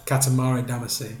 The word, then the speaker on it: Katamari Damacy: My something Karamori or something like that, right? – Katamari Damacy.